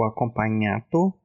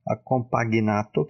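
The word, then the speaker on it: accompagnato: Tem o seco, o acompanhato, que é com dois Cs também, acompagnato, que se escreve acompanhato, e o instrumental.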